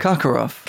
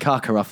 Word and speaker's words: Karkaroff: It's because I can't pronounce his second name. Igor Karkaroff. – Karkaroff, that's it.